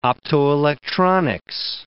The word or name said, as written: optoelectronics